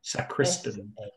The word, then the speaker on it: Sacriston: I don't know if that's pronounced correctly. It's Sacriston. Sacriston. – Sacriston. – County. Sacriston. Yeah. Okay.